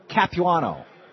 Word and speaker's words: Capuano: Congressman Michael Capuano.